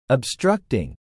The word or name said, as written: obstructing